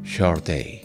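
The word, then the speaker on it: Sade: Cuesta pensar que cuando Epic lanzó a Sod por primera vez al escenario musical, se sintieran obligados a señalar que su nombre se pronunciaba Shortay.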